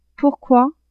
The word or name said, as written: pourquoi